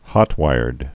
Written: hot-wired

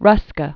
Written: Ernst Ruska

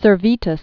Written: Servetus

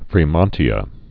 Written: fremontia

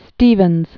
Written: Stephens, Alexander Hamilton

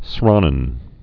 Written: Sranan

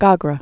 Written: Gogra

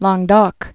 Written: Languedoc

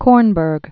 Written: Kornberg, Arthur

